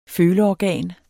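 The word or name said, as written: FØLEORGAN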